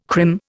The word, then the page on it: crim: Indian